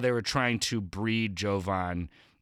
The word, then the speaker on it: Jovan: I believe it's Jovan, P.S., with a hard J, with a hard J.